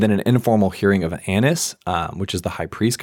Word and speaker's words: Annas: But John is the only one that highlights he went to Annas. If that's how you say his name, it makes sense to me. But I like that pronunciation.